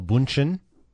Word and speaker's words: Bündchen: Tom Brady has had a relationship with Gisele Bündchen.